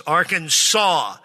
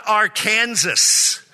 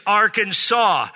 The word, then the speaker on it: Arkansas: The only acceptable pronunciation is in three syllables with the final S silent. The A in each syllable is the Italian sound and the accent on the first and last syllables, Arkansas. So those of you from the West, it's not Arkansas, okay? That's possessive, like we own Kansas. No, it's not Arkansas, it's Arkansas.